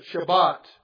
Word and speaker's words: Shabbat: Well, we know this word Sabbath comes from the Hebrew word Shabbat. That's the way I believe we pronounce it, Shabbat.